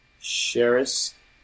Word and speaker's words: Sheres: So on behalf of myself, Evan Sharris, you can find me on Twitter at Evan Sharris. – Spelled like his name in the bottom third there. – Spelled like that, Evan, E-V-A-N, Sharris, S-H-E-R-E-S.